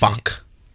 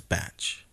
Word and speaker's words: Cumberbatch: Oh, isn't the villain Benedict Cumberbatch in the second one? – Sherlock Holmes.